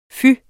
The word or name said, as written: fy